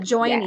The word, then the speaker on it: Joyrney: You talk about the journey to purpose, the joyrney.